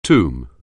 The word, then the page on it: tomb: anglais